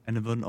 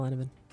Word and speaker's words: Enevun: And also Enovan on Twitter and Enovan on YouTube, where I upload edited down versions of streams. – Oh, that's awesome. And how do you spell Enovan? – Enovan is spelled E-N-E-V-U-N.